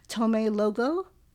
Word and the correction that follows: Lomé: is that right? – Yeah, in Lomé, Togo, exactly.